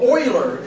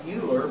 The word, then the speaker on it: Euler: Or Euler, it's spelled like it looks like Euler, but it's really Euler.